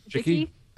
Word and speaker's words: Wojcicki: Wojcicki? – Wojcicki.